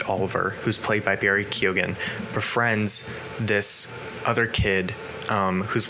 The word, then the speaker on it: Keoghan: Keoghan. Barry Keoghan.